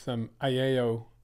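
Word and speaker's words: Ayao: I'm very impressed with Aiello. Three syllables in that first word, by the way. Four letters, three syllables.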